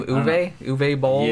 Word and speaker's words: Uwe Boll: How do you pronounce his name? Uwe Boll? – Yeah.